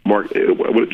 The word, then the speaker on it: Markkanen: It's Markkanen.